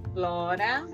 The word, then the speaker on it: Flora: Então repete, arroba Flora Belchior. Belchior, eu vou, eu vou falar o nome, como se escreve, né? Uhum. F-L-O-R-A, Flora, B-E-L-C-H-I-O-R, Belchior, underline, oficial.